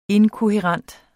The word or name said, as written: inkohærent